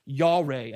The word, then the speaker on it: yare: Now, the word fear in the Hebrew is yare. And I have no idea if I'm pronouncing that correctly. So any Hebrew scholars? Excellent. I pronounced that correctly.